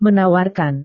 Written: menawarkan

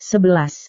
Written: sebelas

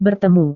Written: bertemu

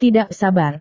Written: tidak sabar

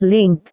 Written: link

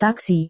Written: taksi

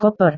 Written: koper

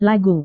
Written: lagu

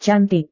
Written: cantik

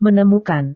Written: menemukan

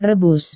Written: rebus

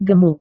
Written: gemuk

